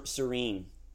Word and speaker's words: serene: How you say serein? Serenity? – Calm, yes, like, yes, serene. – Serene, yeah. Serene. You just have to change a little bit the pronunciation and we're good.